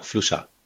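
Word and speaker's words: Fluxà: Und ist das richtig ausgesprochen, Fluxar? – Genau, Fluxar. Das ist hier Mallorquin.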